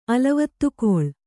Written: ಅಲವತ್ತುಕೊಳ್

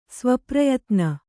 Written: ಸ್ವಪ್ರಯತ್ನ

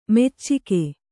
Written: ಮೊಚ್ಚಿಗ

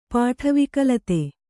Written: ಪಾಠವಿಕಲತೆ

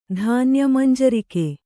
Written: ಧಾನ್ಯಮಂಜರಿಕೆ

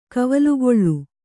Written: ಕವಲುಗೊಳ್ಳು

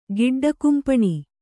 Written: ಗಿಡ್ಡಕುಂಪಣಿ

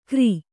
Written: ಗರಿ

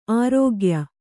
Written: ಆರೋಗ್ಯ

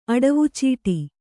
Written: ಅಡವುಚೀಟಿ

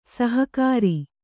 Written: ಸಹಕಾರಿ

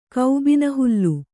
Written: ಕೌಬಿನಹುಲ್ಲು

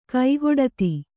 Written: ಕೈಗೊಡತಿ

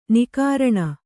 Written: ನಿಃಕರುಣಿ

